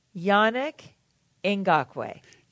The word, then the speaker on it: Yannick Ngakoue: Yannick Ngakoue.